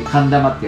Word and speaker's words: 寒玉: 寒い玉って書くような字で寒玉って読むん